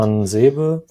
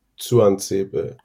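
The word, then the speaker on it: Tuanzebe: Stimmt, Tuan Sebe wird da so ausgesprochen. – Genau. Ich habe mir ein Twitter-Video von Aston Villa mal angeguckt. Das T vorne spricht der selber wie so ein Tuan Sebe.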